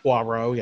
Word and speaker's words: Poirot: Poirot, yeah.